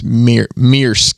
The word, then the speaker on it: Maersk: I think you pronounce it Maersk, by the way.